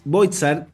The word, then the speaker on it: Boizard: Ella es Alejandra Pérez Boitzar, ahí me corrige Ale si lo pronuncié bien. Eh, sí, está Buenísimo.